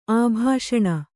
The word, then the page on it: ಅಭಾಷಣ: abhāṣaṇa